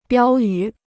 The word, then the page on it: 标语: biāo yǔ